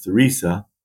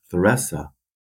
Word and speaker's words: Theresa: When he retired, he moved up here and started the village of Theresa. Well, the Germans came in and they pronounced it Theresa. So it's both Theresa and Theresa.